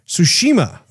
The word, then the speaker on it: Tsushima: Tsushima. Is that how you pronounce it? – Tsushima.